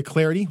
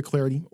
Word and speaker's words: Clardy: Or on Twitter at Troy Clarity, last name is spelled C-L-A-R-D-Y, at Troy Clarity or hashtag TreeCast.